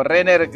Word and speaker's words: Renner: Y Benjamin Renner, yo creo que como Renner, el, que hace Hawkeye, se, se, se pronuncia Renner.